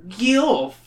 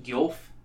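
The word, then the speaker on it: Guelph: Gylph. – I don't know how to pronounce that. It's G-U-E-L-P-H. Gylph? – Gylph. – Yeah, no. – Gylph.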